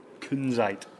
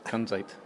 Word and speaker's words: Kunzite: Kunzite. – Kunzite.